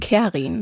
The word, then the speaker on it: Kerin: Und äh, meine Mutter hat damals eine Engländerin in ihrer Klasse gehabt und das war eine Kerin, so wie die es denn hier in Deutschland ausgesprochen haben. Und dann hat sie das alles ein bisschen abgewandelt und dann wurde ich eine Kerin mit einem R.